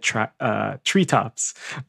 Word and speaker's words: TRITOPS: But it looks like it's spelled like Tritops, but the Korean it's read Treetops.